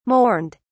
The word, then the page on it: mourned: British English